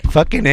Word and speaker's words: Fucking: And it's pronounced Fucking.